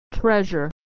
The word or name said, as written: treasure